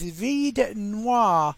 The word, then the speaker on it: Vide Noir: That was called Lost in Time and Space, and it was from Lord Huron, H-U-R-O-N, and the album title, I'm not sure how you pronounce it actually, is Vide Noir. That's V-I-D-E and N-O-I-R.